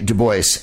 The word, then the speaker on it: Du Bois: Du Bois, he pronounced it that way. How do I know? Because I was corrected in Chicago.